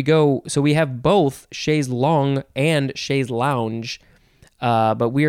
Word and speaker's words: chaise longue: No, it's chaise longue, aha, chaise longue, because it is spelled L-O-N-G-U-E, it's like tongue with an L, and it's pronounced long, and tongue, you'd say chaise longue, it's similar, but it's not the same, chaise longue.